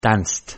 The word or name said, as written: danced